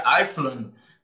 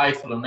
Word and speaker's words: Einfühlung: Seria, seria é, a, a, pronúncia mais pesada, né? Porque é um termo em alemão, seria Eifland. Mas a gente acaba deixando um pouco mais fugindo para o inglês, vai mais para o Eifland, né?